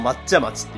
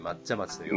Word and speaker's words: 松屋町: 皆さん、松屋町って松、木の松にね。屋根の屋町、うん。でも抹茶町って言うんですよね。松屋町と書いてるのに抹茶町と呼ぶ。